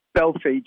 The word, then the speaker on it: Belfi: Is it Jordan Belfi? Jordan Belfi? How do we pronounce his last name? – Belfi.